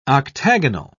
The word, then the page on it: octagonal: British English